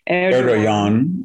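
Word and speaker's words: Erdoğan: And so Erdogan, I'm going to say his name wrong, but Erdogan, yes.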